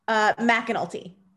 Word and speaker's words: McAnulty: McAnulty.